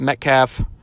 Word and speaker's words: Metcalf: So as I'm looking at Metcalf, that is, I'm saying his right name, his last name right, right? It's Metcalf.